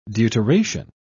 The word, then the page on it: deuteration: British English